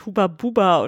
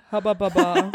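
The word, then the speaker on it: Hubba Bubba: Heißt das eigentlich wirklich Huba Buba oder ist es einfach falsch ausgesprochen? So ein bisschen so wie Mirakel. – Naja, das ist amerikanisch. Wahrscheinlich heißt es Haba also Baba. Es Haba Baba, ja.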